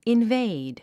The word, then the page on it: invade: invéid; インベイド